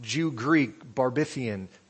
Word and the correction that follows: barbarian: how do you say that? Barbarian, Scythian, Jew.